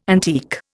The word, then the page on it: antique: Indian